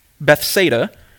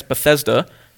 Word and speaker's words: Bethsaida: So Jesus and his disciples traveled to a fishing village, Bethsaida. Being in Maryland, I will sometimes mispronounce this when I read the text as Bethesda, not the same place.